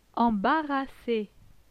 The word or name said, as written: Embarrasse